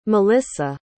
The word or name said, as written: malissa